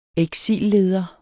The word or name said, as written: eksilleder